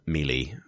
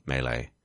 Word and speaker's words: melee: And it was, I thought it was Melee for like the longest time. – Yeah, me too. – And then, yeah, now it's obviously Melee.